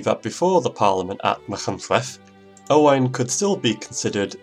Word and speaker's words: Machynlleth: Machynlleth.